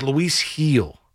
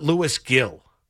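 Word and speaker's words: Luis Gil: Like, the man's name is Lewis Heel, not Lewis Gill. Like, it's Lewis Heel. That's his name.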